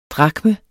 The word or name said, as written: drakme